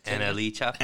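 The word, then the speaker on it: NLE Choppa: NLE Chopper. Did I say his name right? – NLE Chopper.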